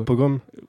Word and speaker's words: pogrom: Euh, mais donc euh, voilà, ils continuent à faire euh, leur, leur polgrom. voilà, c'est comme ça que ça a été qualifié par, par ces gens. que J'ai mal prononcé. Je pense. Vas-y. – Hein, leur pogrom Pogrom, pardon. J'ai rajouté un petit L.